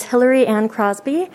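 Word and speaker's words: Hillary-Anne Crosby: My name is Hillary Ann Crosby. That is spelled H-I-L-L-A-R-Y hyphen A-N-N-E, last name Crosby, C-R-O-S-B-Y.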